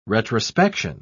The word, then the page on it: retrospection: British English